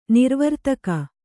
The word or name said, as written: ನಿರ್ವರ್ತಕ